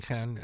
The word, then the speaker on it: Caen: Was taking Caen, I have trouble pronouncing that because I know Caen, C-A-N-N-E-S, but C-A-E-N is what we're talking about.